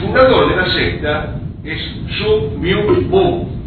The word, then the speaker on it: Sun Myung Moon: Sun se escribe S-U-N, Myung como suena pero con Y, Myung, y Moon con doble O.